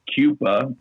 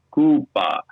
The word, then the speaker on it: Coupa: We are Finnish, so that means that you have to say not Coupa, but you say it, you pronounce it Coupa.